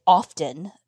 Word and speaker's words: often: Well, I almost stopped you there and said, no, I never say often because the T is silent.